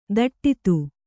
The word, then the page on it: ಧಡೂತಿ: Kannada